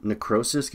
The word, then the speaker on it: necrosis: The term necrosis gets brought up in this film, everybody. I don't know if I'm pronouncing that right. – Yeah, that is.